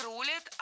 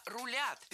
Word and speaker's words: рулят: Все больше усиливается ощущение, что вот мы дурилки картонные, а рулят люди, которые в тени. – Не рулят, а рулят. Переносим ударение за корень и получаем правильный вариант. Рулят, рулит, рулим, рулишь.